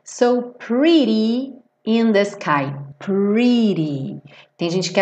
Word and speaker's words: pretty: Cuidado aqui, ó. Pretty. Não pode ser pretty. Porque a gente tem um R e o R em inglês é enrolado, né? Como em porta, per, né? Então, pre. E aí aqui, como tá pintadinho de azul, ri-ri-ri, ri-ri-ri. Igual na palavra guri, pretty, tá?